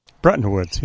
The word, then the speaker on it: Bretton Woods: Bretton Woods. Am I saying that right? – Bretton Woods, yes.